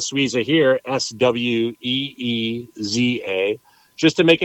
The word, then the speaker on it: Suiza: And so Sweeza was born, and the name Sweeza is just an easy way to say Super Quesadilla Sweeza, which is spelled S-U-I-Z-A, which is translated into Swiss.